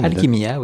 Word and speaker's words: Alchimia: Je prononce correctement. – Alchimia, oui mais une formation scientifique qui a quand même aiguillé, qui a orienté une partie de l'écriture.